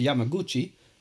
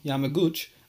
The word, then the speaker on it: Yamaguchi: Continuing on, the man identifying himself as Dr. Yamaguchi, I said that wrong, Yamaguchi, arrived wearing an armband bearing the label Metropolitan Office, City Hall of Tokyo.